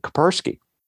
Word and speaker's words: Kaspersky: One of the better known anti-malware makers for Windows is a Russian company, Kaspersky. Yeah, is that how you say it? – Yes.